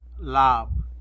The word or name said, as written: lab